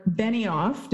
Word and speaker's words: Benioff: Now, as the Lynn and Mark Benioff, did I say that correctly? – Yep.